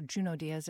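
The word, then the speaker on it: Junot: Hilary Mantel, Juno Diaz, or Juno Diaz as he pronounces his name.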